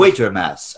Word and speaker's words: Quatermass: I'm going to Quatermass and the Pit.